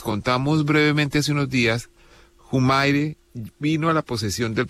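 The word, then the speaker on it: Humire: Joseph Humair, pero como se pronuncia originalmente en Bolivia, Humair, es uno de los funcionarios más importantes del Pentágono, como se conoce al Departamento de Defensa de Estados Unidos.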